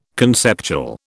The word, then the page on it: conceptual: British English